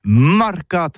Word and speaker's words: marcats: Eh bien, d'ici à toutis, le mercat, de l'Uni ou le mercat des rebelles, mais en Gascogne toulousaine, c'est prononcé sur les marcats, avec un A.